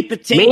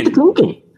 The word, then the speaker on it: Patinkin: I was in it early when Maddie Patton, oh, how do you say his last name? – Mandy Patinkin. – Mandy Patinkin.